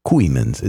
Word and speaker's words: Kooymans: They formed in 1961 and were together until the death of their guitarist, George, I think you say, pronounce his last name, Kooymans. Kooymans. It's K-O-O-Y-M-A-N-S. Kooymans.